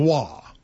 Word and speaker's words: Roy: Yeah, R-O-Y, but it's pronounced Raw.